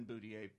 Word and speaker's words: Boothillier: It's Michael Boutier, which is how you – it's not how you introduce – my real name is Michael Boutier, but my YouTube channel is Boutier, and I'll spell that. Michael, first of all, M-I-C-H-A-E-L, and then Boutier, B-O-O-T-H-I-L-L-I-E-R. Phonetically, it's Boot Hillier.